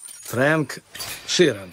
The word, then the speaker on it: Фрэнк Ширан: Фрэнк Ширан, я правильно произношу? – Да, все правильно.